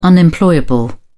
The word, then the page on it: unemployable: British English